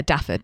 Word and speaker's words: Dafydd: Yeah, Dafydd. That's how you say it. Yeah. That's how you say it. Dafydd.